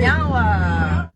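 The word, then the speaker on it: niá:wen: So in our language, we say nyawa, which means thank you. – Nyawa. – Nyawa. – I said it right? – Yeah, you did. – Nyawa.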